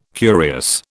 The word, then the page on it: curious: British English